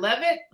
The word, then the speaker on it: Leavitt: How do is it Levitt? How do I pronounce it correctly? – That is correct.